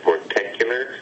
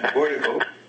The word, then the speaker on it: vortical: How would you say that? Vorticular? – Vortical.